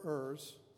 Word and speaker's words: err: He never errs. I forgave the English teacher who said it's pronounced err, because I'd always say he errs.